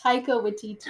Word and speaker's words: Taika Waititi: It's Taika Waititi.